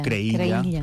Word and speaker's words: Creïlla: O Creïlla, pronunciada a la valenciana.